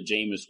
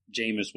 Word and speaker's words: Jameis: If you guys watch football, he's kind of like the Jameis Winston of disc golf. Or he's kind of like the Jameis Winston of disc golf.